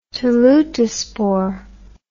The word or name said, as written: teleutospore